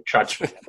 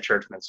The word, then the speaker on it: Czuczman: I had like two like really good chances to, I guess, change my name and pronounce it properly, Czuczman. But when I went to like college at Lake Superior, back then they're like, yeah, do you want to say it properly? And then I'm like, no, my family said it this way. Whatever, doesn't really think. We've always said Czuczman, so whatever.